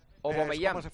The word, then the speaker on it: Aubameyang: Aubameyang, no, pero hay que pronunciarlo bien. – Es mejor que digas O. Aubameyang.